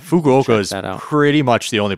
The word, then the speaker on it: Fukuoka: But I'll save the rest for Fukuoka.